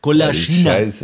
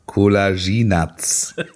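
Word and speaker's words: Kolašinac: Kulaschinatz. Scheiße. Ja. Der heißt Kollaginats.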